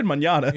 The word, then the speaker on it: mañana: Manana. Is it mañana? You said mañana.